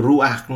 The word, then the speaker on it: ruach: Trong tiếng Do Thái cái từ hơi thở là ruach khi mà chúng ta thở đấy thì cái từ ruach nó phát âm nó giống như vậy thở ruach đấy ruach nó nó nó giống như là cái âm thanh của hơi thở của hơi mình thở ấy